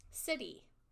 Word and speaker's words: Siddhi: So, once you're done, like, realizing your gift and you're at that level, you can graduate to City, which is, it's spelled S-I-D-D-H-I. But it's pronounced like city, like you live in a city, but with a D, mm-hmm.